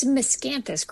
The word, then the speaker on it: miscanthus: How do you pronounce that? What is it? – It's miscanthus grass.